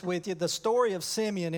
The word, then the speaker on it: Simeon: I was reminded about this story of, I call him Simeon. You may call him Simon. It's spelled S-I-M-E-O-N in the scripture, and my southern heritage says it's Simeon.